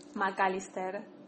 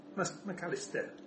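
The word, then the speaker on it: Mac Allister: So how do you say his name? – Magalitaire. – McAllister. Yeah, that's right, yeah. – It's just that you do, like, the McAllister that we don't do, but I think it should be that, like, you say it.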